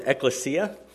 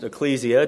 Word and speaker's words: ekklesia: The Greek word in Scripture that's translated church in the Bible is ekklesia. Sometimes that's pronounced ecclesia, depending on your pronunciation of it. But ekklesia, and it essentially means called out ones.